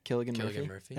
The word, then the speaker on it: Cillian Murphy: Maybe Cillian Murphy. – My mind. – Wow, wait, did I just say that right? – Cillian Murphy. – That is right? – Yeah.